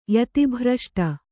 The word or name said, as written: ಯತಿಭ್ರಷ್ಟ